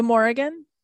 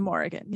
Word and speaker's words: Morrigan: Oh, the Morrigan. – The Morrigan. – The Morrigan.